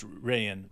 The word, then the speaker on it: Rayan: Ryan Rupert at 14. – It's Rayan. Rayan Rupert. – Yeah, you're right. I always just read it Ryan.